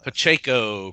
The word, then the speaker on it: Pacheco: And Carlos Pacheco drew it. I probably butchered that. – Pacheco.